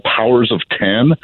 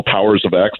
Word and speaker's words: Powers of X: He's got the House of X, and the sister title called Powers of Ten, or Powers of X, depending on how you want to pronounce it.